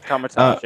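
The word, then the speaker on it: Kamar-Taj: Carmitage, yes.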